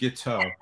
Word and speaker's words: Guiteau: Like guitar, except Guiteau.